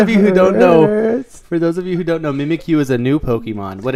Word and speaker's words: Mimikyu: It's Mimikyu. That's how you pronounce his name.